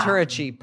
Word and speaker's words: Faturechi: Is it Faturechi? Is that how you say your last name? – Faturechi. Yeah. – Faturechi.